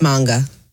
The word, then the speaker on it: manga: It's not a manga? – No, it's manga. – I've been saying it wrong. I did not know that. I guess I've never heard anybody say it out loud. So, all right. Manga.